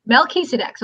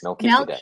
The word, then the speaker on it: Melchizedek: Melchizedek. So it's Mel, like Mel Gibson. – Okay. – Keys, like a set of keys. So it's Mel Keys. A deck, like a deck of cards. – Melchizedek.